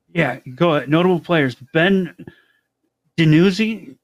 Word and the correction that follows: DiNucci: Dinucci? Is that how he said? – Dinucci. He was just oh, on national TV Italian.